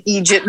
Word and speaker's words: eejit: It's spelled E-E-J-I-T. It's Irish and Scottish form of idiot. Egypt, long E.